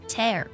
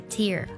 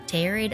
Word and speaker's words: tear: 不过它的发音呢是 tear，可不是 tear，好不好？它读作。 tear，tear，比如说我要把它撕开，我们就可以说 tear it open。